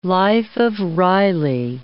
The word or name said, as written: life of Riley